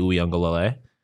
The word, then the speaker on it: Uiagalelei: It could go, this guy could go DJ Uiagalelei.